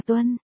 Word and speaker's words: Tuân: Văn Quân quơ quơ tờ giấy, cầu Mộ Sinh, chữ đó đọc là Tuân.